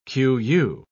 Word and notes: qu.: British English

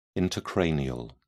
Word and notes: intracranial: British English

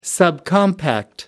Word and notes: subcompact: American English

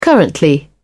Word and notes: currently: American English

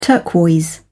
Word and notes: turquoise: British English

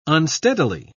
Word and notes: unsteadily: British English

